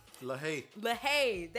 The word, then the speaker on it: LaHaye: And so we're going to discuss some different personality types, and we'll kind of gear towards personality types by Tim LaHaye.